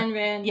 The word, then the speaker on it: Ayn Rand: It is Ayn Rand.